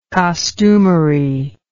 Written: costumery

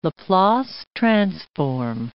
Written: Laplace transform

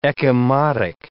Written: ecchymotic

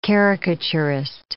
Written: caricaturist

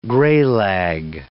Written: greylag

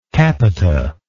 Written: capita

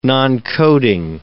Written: noncoding

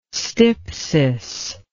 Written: stypsis

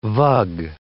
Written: vug

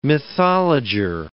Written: mythologer

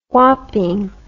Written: wapping

